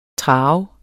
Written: traver

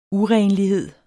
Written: urenlighed

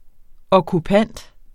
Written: okkupant

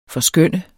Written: forskønne

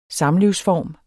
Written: Samlivsform

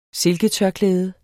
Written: silketørklæde

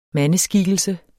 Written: mandeskikkelse

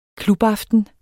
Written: klubaften

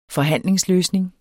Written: forhandlingsløsning

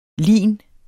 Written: linets

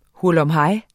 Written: HURLUMHEJS

